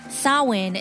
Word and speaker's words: Samhain: Samhain is the name Halloween in Irish, which has got an unusual spelling, S-A-M-H-A-I-N. – But it's pronounced Samhain.